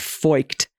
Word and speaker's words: Feucht: It is indeed pronounced Foicht. Oh, soft C-H, Feucht, as you know your German language chops, A-G.